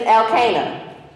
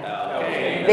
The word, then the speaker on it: Elkanah: His name is Elkanah. Okay, what's the guy's name? – Elkanah. – Very good. Y'all all sound like Southerners like me. I love it. There's a guy, his name is Elkanah.